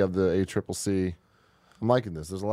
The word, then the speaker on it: ACCC: Uh, Mark says ACCC is widely accepted to be pronounced as a triple C.